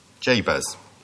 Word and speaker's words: Jabez: His name is what it says on the tin, Jabez.